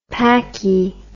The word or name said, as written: packie